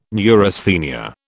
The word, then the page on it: neurasthenia: British English